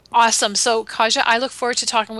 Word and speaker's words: Kaja: We have Kaja Robinson, and I told her that's a beautiful name. And it is. – It's spelled just like it sounds. K-A-J-A.